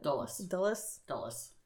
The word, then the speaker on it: Dulles: On July 10th, at Dulles International Airport, is that how you pronounce it? – Dulles. – Okay. – It's Dulles.